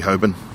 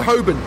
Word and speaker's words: Holborn: And so Holborn, many people pronounce as Holborn, but is the jury still out on that? Is it Holborn? Is it Holborn? Is it Holborn or Holborn? So I prefer Holborn. – Do you know where the name? – I won't pronounce the L.